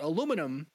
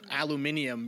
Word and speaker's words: aluminium: This week's question is about aluminum, or as Katie always likes to joke, aluminium, because that actually is how you're supposed to pronounce it. But whatever. – If you're British.